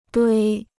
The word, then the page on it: 堆: duī